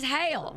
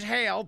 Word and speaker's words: hell: And you have to say it so it rhymes. Raise hail. – Raise hail, praise Dale. – Praise Dale. Otherwise, it doesn't rhyme.